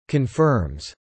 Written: confirms

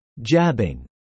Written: jabbing

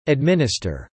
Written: administer

